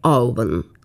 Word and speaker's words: Eoin: Píše sa to Eoin a vyslovuje Owen. Owen Colfer.